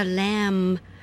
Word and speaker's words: Lamb: 像我们讲小羊，小羊叫 Lamb, L-A-M-B, 那个 B 也没有发音。